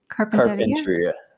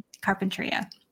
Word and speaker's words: Carpinteria: So for those who don't know, Carpinteria, I don't think I ever say that right. How do you say it again? – Carpinteria. – Carpinteria.